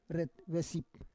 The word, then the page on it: ret vesip: Roon